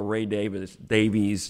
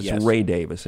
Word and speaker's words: Davies: I'm going to go to a Ray Davies song. By the way, Ray Davies, that's the way most people pronounce it. We had Ray himself on the show. Ray Davis, in case anybody's going to complain.